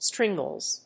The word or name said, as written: stringless